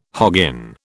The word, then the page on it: hoggin: British English